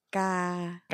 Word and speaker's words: cagada: You're full of cagada. Oh, did I say it right? – No. – Whatever. – It's cagada. – Cagada.